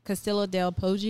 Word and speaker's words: Castello del Poggio: Poggio. – Poggio. Castello del Poggio.